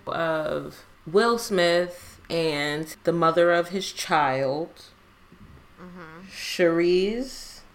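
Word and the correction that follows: Sheree: I think that's how you say her name. – I think it's just Cherie.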